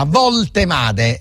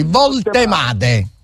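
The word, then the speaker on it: Woltemade: Voltemade è semplicissimo perché la W si legge V in tedesco, come sta scritto così lo leggiamo, non facciamo errori, Voltemade.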